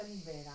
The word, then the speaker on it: Libera: Ah, si pronuncia Libera anche qui. – Sì, Libera ho imparato lì perché era Libera.